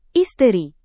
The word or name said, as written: isteri